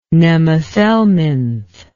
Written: nemathelminth

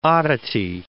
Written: oddity

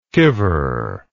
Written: kiver

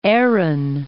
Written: Aran Islands

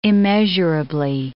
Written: immeasurably

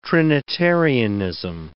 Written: Trinitarianism

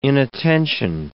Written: inattention

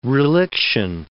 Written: reliction

